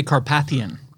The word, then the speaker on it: Carpathian: He said, there's a lot of time and opportunity for Viggo, the Carpathian, is that how you say it? – I would say Carpathian.